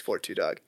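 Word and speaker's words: 42 Dugg: Because Big Sean was calling them out because they were giving him a bunch of Detroit artists and they kept pronouncing the names wrong. He's like, no, put some respect on that guy. It's 4-2 Doug.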